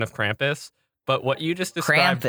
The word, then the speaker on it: Krampus: But Krampus, said correctly by our accent, is Krumpus, which sounds like you're like crumping.